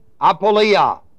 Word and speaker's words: apoleia: The word destruction here is the Greek word apoleia, A-P, long O-L-E-I-A.